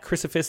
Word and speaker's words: Crysphus: crucifix. That's C R Y. You're gonna have to spell it. yeah, C R Y S P H U S. So it's crypto and Sisyphus jammed together.